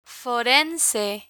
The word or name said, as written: forense